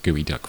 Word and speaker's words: geoduck: The funny part was the guys on stage, and there's a particular kind of shellfish called a gooey duck, which is spelled G-E-O duck, right? It's a G-E-O, so geoduck. – Yeah, but it's pronounced gooey duck for some reason.